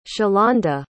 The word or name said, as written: shalonda